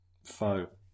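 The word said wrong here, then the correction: Pho: I pronounced it wrong. It's pronounced fur, so technically I didn't say a word.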